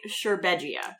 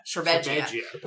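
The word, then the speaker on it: Šerbedžija: Rade Sherbegia. – Sherbegia. – Like, it sounds like sharp edge. – Yes. Yeah. Sherbegia. – And he's just so great. I fell in love with Rade Sherbegia a long time ago as a young movie-watching lady when he took the stage and said, "Friends, countrymen, Russians!"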